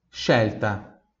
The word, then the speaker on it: scelta: bravíssimo E agora o último grupo de pronúncia, a última vogal é a vogal E aqui, então SCE também vai ter o mesmo som de X, só que agora, obviamente, seguido de E, então X, né? Por exemplo, a palavra Shelter, Shelter, repita em voz alta, Shelter, Shelter, scelta. Mais uma vez, Shelter se escreve S-C-E-L-T-A, Shelta.